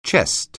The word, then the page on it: chest: English